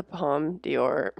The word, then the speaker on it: Palme d'Or: What is the Palme d'Or? Did I even say it right? – Yeah, that's right.